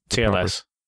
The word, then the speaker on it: TLS: SSL is TSL. And it was a well, actually, but then I started looking it up, and sure enough, it looks like TSL is TLS.